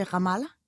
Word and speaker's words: Ramallah: Nej, Hjalp, på et tidspunkt, så rejser du til Ramallah.